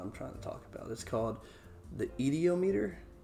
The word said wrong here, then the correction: ideomotor: ideomotor effect. There it is. The ideomotor effect.